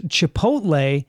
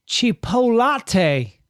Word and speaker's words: Chipotle: I know someone who pronounces Chipotle, Chipolate.